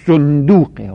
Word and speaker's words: صندوق: مثلا یه صندوقی، صندوق یعنی لغت صحیحش صندوقه، وزن فعلوله صندوق، مثل زنبور، یعنی زنبور، این ما غلط تلفظ میکنیم، نگید هم غلط مشهور، غلط مشهور باید در لغت صاحب لغت باشه، زنبور، صندوق، اسفور میشه.